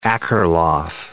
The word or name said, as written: Akerlof